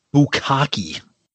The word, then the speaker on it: bukkake: Did I say that correctly? – Bukkake.